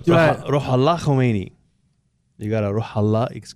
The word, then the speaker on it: Khomeini: So how would you do it in Farsi? – It's Ayatollah Ruhollah Khomeini.